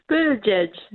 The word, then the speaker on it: Buttigieg: Buttigieg. It's like saying "edge" twice.